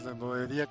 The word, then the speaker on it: Nerdolería: Y pues recuerden que nosotros estamos en Facebook como Nerdolería y en YouTube también nuestro canal es Nerdolería con acento en la I.